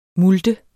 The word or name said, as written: MULTENS